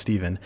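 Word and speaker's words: Stephan: My name's Steven, spelled with a PH and an A, so it should be pronounced Stefan, but it is actually pronounced Steven.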